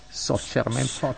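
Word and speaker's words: Soccerment: Bravissimo, si scrive Socerment, si pronuncia Socerment. – Anche Socerment, giustamente. S-O-C-C-E-R-ment.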